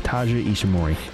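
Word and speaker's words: Taiji Ishimori: How do you pronounce it again? – Taji Ishimori.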